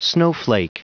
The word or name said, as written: snowflake